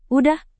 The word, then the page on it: udah: indonesian